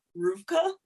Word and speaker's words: RVCA: Well, I learned this from my partner because I always thought it was RVCA, but apparently you pronounce it because it's like skateboard and surf, and I'm like, I'm not a boarder, but it's pronounced Rufka. Rufka?